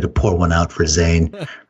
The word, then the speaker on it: Zayn: and I think it's Jane or One Direction. – Zane, is that who left? Zane. That's spelled Z-A-Y-N.